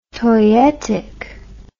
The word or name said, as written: toyetic